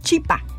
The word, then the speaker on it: chipa: Y en quechua, el mbuyapé, que como le decían, como apelmazado o bulto, en quechua se pronunciaba chipa.